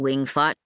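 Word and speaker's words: Wing Fat: Still busy running Wing Fot in Westwood? She'd pronounced the name of it correctly, making the fat sound more like fot.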